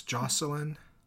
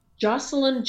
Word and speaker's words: Joshilyn: It's Jocelyn. – Jocelyn Jackson, but it's Jackson. Spelled J-O-S-H-I-L-Y-N. And the H is silent.